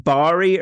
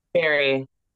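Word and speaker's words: Barrie: Is it pronounced Bari or Barry? – Barry. – Barry. – When you were saying Bari, and I'm like, I don't remember going to a bar.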